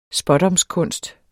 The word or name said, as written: spådomskunst